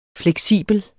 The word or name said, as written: fleksibel